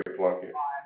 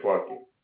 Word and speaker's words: Plunkett: Guinevere Plunkett. – I'm sorry, what is it? – Guinevere Plunkett.